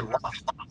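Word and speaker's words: Lough: But someone sent me one to tear down and Brian Lau or Lowe, I don't know how you pronounce his name. It's L-O-U-G-H. – Actually, Loft. – Loft? Loft. – Like Loft. I watched some of his videos. It's like Brian Loft. – Okay. Cough.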